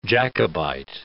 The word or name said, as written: Jacobite